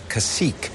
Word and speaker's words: Cacique: Now, you also have a line of intimate apparel, I noticed, Cacique. I'm wondering if you can describe what's going on there. – We're very proud of Cacique, and thank you for pronouncing it correctly.